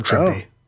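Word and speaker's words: Trimpe: Marie Severin said in an interview that Trimpy rhymes with Shrimpy. So it's Herb Trimpy.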